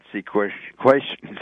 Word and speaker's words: sequestration: I think that here these politicians are screaming and yelling about sequestration.